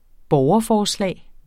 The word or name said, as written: borgerforslag